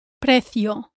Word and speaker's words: precio: Here you need to watch out for the vowels E, I, O. Make sure your E sounds like this. E. E. And that you don't add a short U after the final O. The Spanish O is a pure sound. O. O. In the word precio, practice saying the syllable P-R-E.